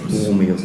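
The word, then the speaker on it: Borromäus: Baromeus. – Ich kann es nur schlecht aussprechen, Baromeus, genau. – Boromeus.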